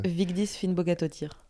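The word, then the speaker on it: Vigdís Finnbogadóttir: Vigdis Finnbogatottir.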